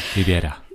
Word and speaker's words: Rivera: Riviera, ich kann es nicht so gut aussprechen. – Rivera.